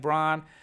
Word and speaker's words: Braun: Got to make sure I call him Brown, even though his name is spelled like Braun.